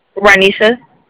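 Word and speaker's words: Roniesha: Ranisha. – Ranisha, okay. How you spell that? – R-O-N. – Uh-huh. I-E-S-H-A. S-H-A.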